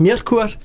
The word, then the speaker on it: Myrkur: Myrkur øh, som øh, Anders Bøtter kalder dem, og de fleste andre, som i virkeligheden udtales Mjertkurt. Øh, hun har et islandsk navn,